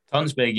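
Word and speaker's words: Tønsberg: I couldn't find sort of much info about the club itself, but is it, I'll not pronounce it right because I'm not Scandinavian, but it's Tonsberg. – Tonsberg, yeah.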